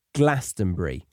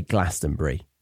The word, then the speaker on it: Glastonbury: I also want to say, the pronunciation is how I've been saying it, Glastonbury. Glastonbury. I heard a few of the North American singers saying Glastonbury. So yeah, don't say that. Say Glastonbury. Glastonbury.